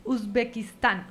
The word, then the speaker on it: Uzbekistán: Uzbekistán. – ¿Dónde queda? – Uzbekistán queda.